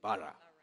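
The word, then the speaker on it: borough: Is that how you say it? Barra. Barra. Yeah.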